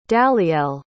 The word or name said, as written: dalyell